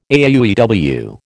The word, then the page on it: AUEW: American English